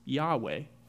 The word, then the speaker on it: Yahweh: This word is pronounced Yahweh.